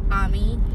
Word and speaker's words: Ami: It, it's spelled like it looks like Amy, but it's Ami. Like I guess it's a French thing.